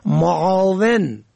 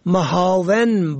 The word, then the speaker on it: معاون: یک کلمه دیگر که زیاد استعمال می شود معاون فرد دوم در یک پست که معنای کمک کننده دارد را محاون با معنای خار کننده نادرست تقریر می کنند که گویا فکر می کنند که ملح ادا کردند.